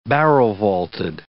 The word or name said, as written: barrel-vaulted